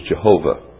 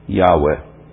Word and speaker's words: Yahweh: Yehowah, Y-E-H-O-W-A-H. This is the word that is often pronounced as Jehovah. Really should be Yehowah, or more properly, really, Yahweh.